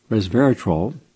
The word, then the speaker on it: resveratrol: From Harvard University, published in Frontiers in Physiology, red wine's resveratrol, trol, three syllables, resveratrol, that could help keep you strong and helps your bones.